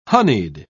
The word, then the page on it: honied: British English